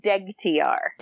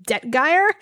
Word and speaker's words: Degtyar: I don't know why when I read it, and would say like, so I was pronouncing it Detgeier. And then as I was reading it again, I'm like, wait, no, the G comes first.